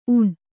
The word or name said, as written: ಊನ